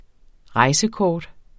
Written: rejsekortet

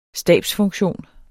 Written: stabsfunktion

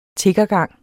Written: tiggergang

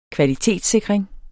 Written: kvalitetssikring

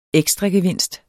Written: ekstragevinst

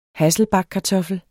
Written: hasselbackkartoffel

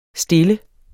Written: stille

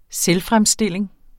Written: selvfremstilling

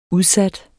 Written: udsat

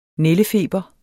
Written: nældefeber

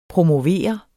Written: promovere

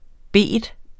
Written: bet

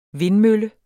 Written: vindmølle

